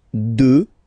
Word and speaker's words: deux: Deux. Final X not pronounced, okay? Deux.